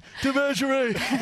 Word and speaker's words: Devashrayee: It's Devashrayee. Are we saying that correctly? – Yeah, that's correct. – Devashrayee.